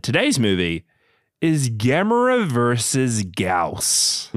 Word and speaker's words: Gyaos: Now, that's how you say it. – Gauss. I think we'll be better than we think we are. – Yeah. Who came up with this monster name and was like, G-Y-A-O-S, that's a great name for a monster.